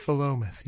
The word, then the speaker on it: Philomath: Philomath, yes.